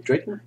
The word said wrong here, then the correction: Daken: I think it's pronounced Daken. – Daken. I've only ever read it.